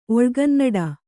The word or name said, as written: ಒಳುಗನ್ನಡ